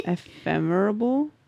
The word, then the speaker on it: ephemeral: Because I'm not saying it right. I think ephemeral. Um, lasting for a very short time is what it means. Oh, okay, okay. Ephemeral. Ephemeral.